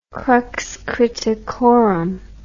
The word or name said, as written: crux criticorum